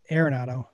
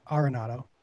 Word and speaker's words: Arenado: Nolan Arenado. Not Arenado, Arenado.